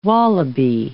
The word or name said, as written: wallaby